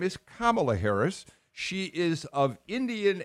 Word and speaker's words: Kamala: Number one, her name is Kamala Harris, and I've noticed that for some reason there are certain Republicans who have chosen to mispronounce the name, maybe accidentally.